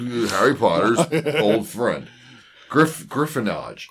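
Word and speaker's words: griffonage: But it's G-R-I-F-F-O-N-A-G-E. It's literally Griffin Age. – Yeah. – Okay. It's Griffinage.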